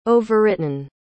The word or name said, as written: overwritten